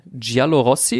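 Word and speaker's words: Giallorossi: Die heißen auch, ähm, jetzt lasst mich nicht lügen, äh, Giallo Rossi ist richtig. Ist das richtig ausgesprochen? Ich glaube schon, ne? – Ja, Rossi steht für Rot.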